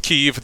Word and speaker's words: Kyiv: And after doing some more research on this whole thing, according to the Ukrainian Foreign Ministry, the Ukrainian Ministry of Foreign Affairs, sorry, I don't know if there's two different things, it should be pronounced Kyiv. That is the traditional Ukrainian language pronunciation of the place,